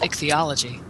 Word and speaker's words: ichthyology: The correct pronunciation was ichthyology.